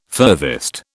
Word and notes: furthest: British English

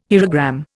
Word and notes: hierogram: Indian

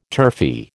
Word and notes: turfy: American English

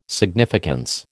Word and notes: significance: American English

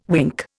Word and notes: wink: Indian

